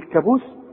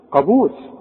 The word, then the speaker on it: قابوس: وعندك بقى في الكلمات البلد بتاعتنا بقى بنت المتداولة، عمرك ما تقول على القانون كانون، تقول كانون قانون، وتقول قابوس ما تقولش ايه، هتقول كابوس قابوس قرار ها قرض. خلاص عرفنا القاف، وارجوك تاخد بالك من نطقها السليم.